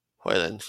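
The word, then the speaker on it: Højlund: if that is how you pronounce his name, I'm not sure yet. It's Hoyland. – Hoyland.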